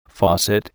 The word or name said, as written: faucet